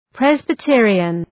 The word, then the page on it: Presbyterian: {,prezbı’tıərıən}